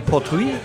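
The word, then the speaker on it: Port Ruighe: Und es gibt tatsächlich nur den Port Rouge. Wie wird der ausgesprochen? Port Rouge.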